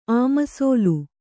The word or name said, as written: ಅಮಸೋಲು